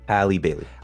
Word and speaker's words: Halle Bailey: Halle Bailey.